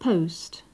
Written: post

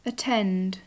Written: attend

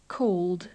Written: called

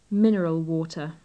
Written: mineral water

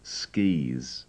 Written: skis

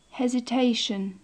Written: hesitation

